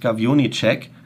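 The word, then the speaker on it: Gajowniczek: Und einer von denen war Francis Gavionicek, aber so spricht sich das aus.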